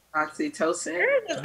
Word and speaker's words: oxytocin: Oxytocin.